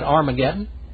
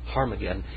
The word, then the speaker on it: Harmageddon: Now, how do you say Armageddon? Is it Armageddon? No. There's no battle of Armageddon. It's a battle of Harmageddon. Harmageddon.